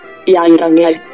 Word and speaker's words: Geiranger: scritto Geiranger. Come si pronuncia? – Geiranger.